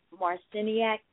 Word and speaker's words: Marciniak: Barbara Marciniak, is it? Am I saying that right, sister? – Yeah.